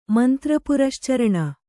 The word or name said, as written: ಮಂತ್ರಪುರಶ್ಚರಣ